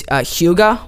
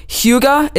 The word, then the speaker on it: hygge: So hygge, it's spelled H-Y-G-G-E. And I don't know. I just kind of said it. It was like hygge. You stress the U or the Y, make it a U.